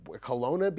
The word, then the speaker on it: Kelowna: First off, even before I ask you any of the questions about what the hell is going on here, you are in Kelowna, B.C.? Am I saying that right? – That's right, man. You nailed it.